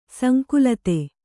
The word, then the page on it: ಸಂಕಲಿತ: kannada